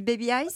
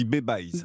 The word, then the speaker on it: Babeyes: On dit Baby Eyes ? – Non, on dit Babe Eyes. – Babe Eyes, c'est une contraction de baby et de eyes en anglais. – D'accord. Ça s'écrit B-A-B-E-Y-E-S.